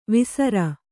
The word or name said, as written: ವೇಸರ